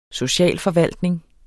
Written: socialforvaltning

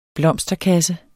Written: blomsterkasse

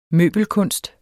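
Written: møbelkunst

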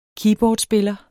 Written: keyboardspiller

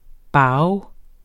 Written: bao

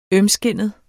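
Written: ØMSKINDET

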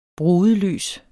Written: brudelys

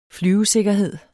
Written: flyvesikkerhed

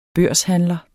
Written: børshandler

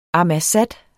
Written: ammassat

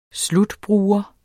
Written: slutbruger

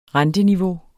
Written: renteniveau

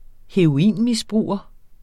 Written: heroinmisbruger

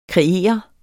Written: kreere

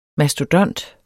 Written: MASTODONTERNES

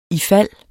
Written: ifald

